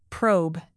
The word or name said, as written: probe